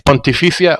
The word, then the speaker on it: Pontificia: Yeah, it's Pontificia Universidad Católica.